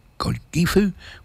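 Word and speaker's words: Godgifu: And on this day, back in 1996, and I presume it's still going, they revived the procession of the legendary Lady Godiva, or Godifu, which is the old Saxon pronunciation, Lady Godiva, where she rode naked through Coventry in England.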